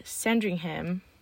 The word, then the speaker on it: Sandringham: In Sandringham, England, United Kingdom.